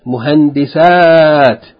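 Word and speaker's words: muhandisat (مهندسات): Whereas in the plural form, the A is a long A. Muslimat, muhandisat.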